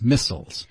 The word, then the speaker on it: missiles: M-I-S-S-I, M-I-S-S-I-L-E-S, missiles. And British say missiles, right? – Oh. – Yeah, a little bit different pronunciation there.